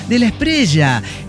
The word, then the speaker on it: Espriella: Me dice, es como estrella. – Estrella, sonrisa de una estrella. Estrella del DF mexicano, de la Espriella, de la Espriella.